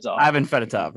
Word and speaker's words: Ivan Fedotov: So to bring it back to what the actual conversation is with the goaltender, whose name I don't want to pronounce. Ivan Fedotov. – Yeah, there you go.